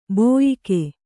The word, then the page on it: ಬೋಯಿಕೆ: bōyike